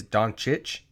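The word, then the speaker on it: Dončić: Not really a joke, but she was talking in the middle about how she was talking to Doncic, and she did clarify it is Doncic, and which is hilarious because Woj pronounced it wrong the entire time.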